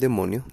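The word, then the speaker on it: demon (δαίμων): En religión, ocultismo y folclore, un demonio, del griego daimon, que se pronuncia demon, es un ser sobrenatural descrito como algo que no es humano y que usualmente resulta malévolo.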